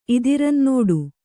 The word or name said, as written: ಇದಿರಂನಡೆ